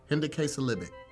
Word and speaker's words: hendecasyllabic: The word is H-E-N-D-E-C-A-S-Y-L-L-A-B-I-C. – This word is... Hendecasyllabic. – No. The emphasis was on every wrong syllable. Hendecasyllabic.